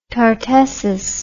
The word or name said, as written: tartessus